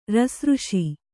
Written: ರಸಋಷಿ

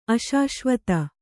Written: ಅಶಾಶ್ವತ

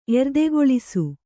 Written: ಎರ್ದೆಕೊಳಿಸು

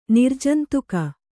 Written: ನಿರ್ಜಂತುಕ

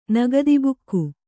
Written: ನಗದಿಬುಕ್ಕು

